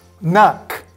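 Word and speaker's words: knock: クーじゃなくてナック。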